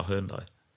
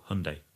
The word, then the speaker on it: Hyundai: So something like an i30, Hyundai i30N, the fastback version of that is a superb car, but it's a Hyundai. Or Hyundai, that's another thing. The pronunciation of, you'll get lots of hate if you say that wrong, but I think it's Hyundai.